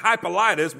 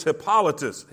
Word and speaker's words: Hippolytus: I've been calling it Hippolytus, but when I looked it up on the computer how to pronounce it, it was Hippolytus.